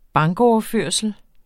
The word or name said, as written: bankoverførsel